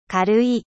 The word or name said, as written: leger